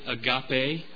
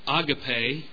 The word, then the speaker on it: agape: Some pronounce it agape, agape love. I pronounce it agape because of the way the term is written out. But either way that you pronounce it, that doesn't really matter.